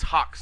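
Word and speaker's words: HAWX: You have between 9/5 and 9/11, you have Tom Clancy Hawks. I guess that's how they say it. – It's Hawks. – Hawks. – Yeah, you know, like the bird, but 2 for the 360 and the PS3.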